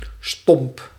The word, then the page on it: blunt: /blʌnt/